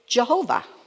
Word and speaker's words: Jehovah: People have interpreted to say Yahweh. Other people have pronounced it Jehovah. So it's pronounced two different ways.